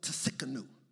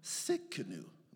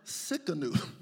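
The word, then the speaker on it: Tsidkenu: It's T-S-I-D-K-E-N-U. The T is silent like the L is silent in salmon.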